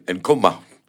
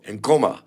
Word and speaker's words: Nkoma: Nkoma. Not uh, Oui, Ouais, Yoko, Nkoma. Joko, en And, avec langage, you know. Je dis le mot comme ça. Ouais, et, et l'emphasis sur la première euh, syllabe, ouais.